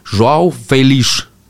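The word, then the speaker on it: João Félix: Jeg jeg trodde masse ting om hvordan det uttales, men ikke sånn som han uttalte det når jeg så han i en sånn videosnutt. For da kikket han rett inn i kameraet, dønn alvorlig, og sa Joao Felice. Og Felice? Felice. Ja. På en sånn brei, sånn spansk eller sånn iberskaktig dialekt uh, nede i Portugal.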